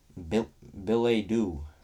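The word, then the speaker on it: billet-doux: This week's Big Word of the Week is Billet Doux. – It is spelled B-I-L-L-E-T dash, I guess, D-O-U-X.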